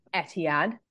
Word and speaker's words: Etihad: Um, but we've also gone away to places like the Etihad.